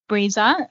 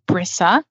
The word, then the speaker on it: Brisa: So yeah, it's pronounced Breeza. – Breeza. – But often people say Brisa because that's how it's spelled, B-R-I-S-A. Breeza in Portuguese it's like an expression.